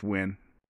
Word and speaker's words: Nguyen: A common Vietnamese last name is Nguyen, and it's spelled N-G-U-Y-E-N. I bet you seen that and didn't know how to say it. It's just Nguyen. Not lose, Nguyen.